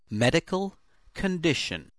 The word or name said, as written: medical condition